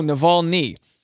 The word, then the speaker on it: Navalny: But last night I watched Navalny. Am I pronouncing that right? No, Navalny.